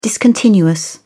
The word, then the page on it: discontinuous: British English